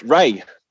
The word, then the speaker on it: Rhea: No, not Rhea, just Ray. – Ray. Okay. – She actually tweeted about it. It's pronounced Ray, like R-A-Y.